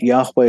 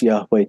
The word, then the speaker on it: Yahweh: And embedded in the Yahweh name are these two H's, a sound, which is almost silent the way we pronounce Yahweh today. But if you go to Proto-Northwest Semitic, which is the ancestor of the modern Semitic language, was voiced like this.